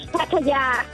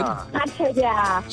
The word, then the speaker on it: Pattaya: The correct pronunciation is Pattaya. – Hang on, could she say that again? – Pattaya. – So there's a long Pattaya. Ya at the end.